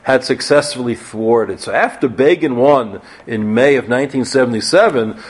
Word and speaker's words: Begin: Most infamously, remember, Time magazine wrote about him, Begin rhymes with Fagin,